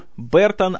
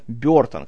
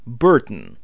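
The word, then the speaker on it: Burton: Объясню, почему я говорю Бертон, а не Бертон, как у нас принято его произносить. Вообще, оригинальное произношение фамилии звучит как Бертон. И вот этот звук в первом слоге, этот бр, это э получается, это нечто среднее между звуком э и звуком и.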